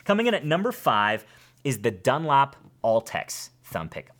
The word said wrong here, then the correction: Ultex: Altex, I should say that properly. Ultex.